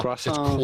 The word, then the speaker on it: croissant: The T at the end of croissant is definitely silent. – That's so do not say croissant, but rather croissant.